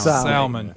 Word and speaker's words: salmon: The L is silent. How many times do we have to tell you? The L in salmon is silent. – Salmon.